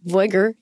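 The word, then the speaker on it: Veuger: We're going to be talking about populism and cannabis, obviously two very different topics, but I'm going to be inviting Stan Verger, or Voiger in the American pronunciation.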